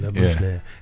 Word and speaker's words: Labuschagne: Is that how do you say it? – Labuschagne? – Labuschagne.